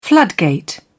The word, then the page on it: floodgate: British English